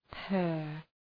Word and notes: purr: pɜ:r

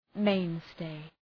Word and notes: mainstay: {‘meın,steı}

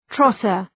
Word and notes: trotter: ‘trɒtər

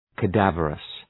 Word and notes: cadaverous: kə’dævərəs

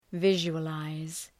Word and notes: visualize: ‘vıʒʋə,laız

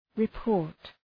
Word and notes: report: {rı’pɔ:rt}

